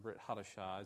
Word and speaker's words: Brit Hadashah: We just haven't found a whole Brit Chadashah, or Brit Hadashah, as it's pronounced in Hebrew, a whole New Testament from early in the church's history.